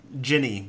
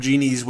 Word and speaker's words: Jinni: I keep wanting to say Ginny, but it's Genie, so... They spell it J-I-N-N-I, which is not a way that I've ever seen Genie spelled before. But, of course, I listen to the audiobook, as I almost always do these days, and it's pronounced Genie throughout, so. – Well, I've seen it several times before spelled out as J-I-N-N-I, so to me, when you...